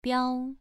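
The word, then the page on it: biao: tone 2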